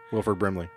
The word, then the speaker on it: Wilford Brimley: Wilford Brimley.